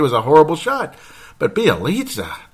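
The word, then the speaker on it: Bjelica: Well, because Sam Mitchell wanted to play Nemanja, now that I can finally say it right, Bielitsa. 22 minutes in the game. The whole fourth quarter, the guy played like shit. Pardon my French, because I try to keep this a family show, but Bielitsa.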